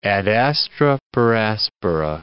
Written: ad astra per aspera

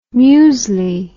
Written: musli

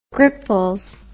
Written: gripples

